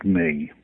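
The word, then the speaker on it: me: It's spelt M-E, but pronounced May, as in the month of May.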